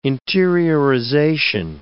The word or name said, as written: interiorization